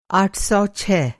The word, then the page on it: आठ सौ छह: آٹھ سو چھ